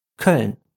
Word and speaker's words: Köln: Das Ö in Köln klingt wie das Ö in schön, nur kürzer. Köln.